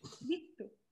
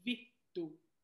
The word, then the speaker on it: vittu: Vittu.